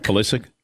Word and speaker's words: Pulisic: I'll say it wrong, but that American soccer player, Christian Pulisic? – Pulisic? – Yes.